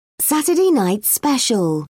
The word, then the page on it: Saturday night special: British English